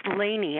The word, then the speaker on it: Lainey: It's pronounced Lainey, actually, like Elaine from Seinfeld show.